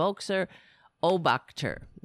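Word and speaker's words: Völkischer Beobachter: It's a very difficult word for me to pronounce. The you know, it means the People's Observer in German. Volksher Obachter, something like that, right? And that was the first Nazi newspaper. And they got progressively worse. And so that was sort of like a moderate Nazi newspaper. And then you got Der Stürmer. So Fox News is like Volksher, how do you pronounce it? The B is silent. The Volksher Obachter, right?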